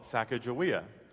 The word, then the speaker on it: Sacagawea: In the book, he uses as a central metaphor the expedition taken by Lewis and Clark and their companion, sometimes called Sacagawea, although linguists now think that perhaps a more accurate pronunciation may be Sacagawea.